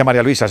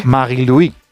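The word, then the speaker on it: Marie-Louise: Está María Luisa, ¿no? – Claro, bueno, sí, es que no se dice María Luisa, se dice en su... – Sí. – Marie-Louis Eta, ¿no? – Así es.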